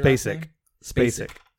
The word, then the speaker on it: Spacek: And you have like Sissy Spacek, I believe is how you say it. – Spacek.